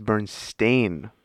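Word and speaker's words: Berenstain: It's Bernstein. Like a stain on your shirt.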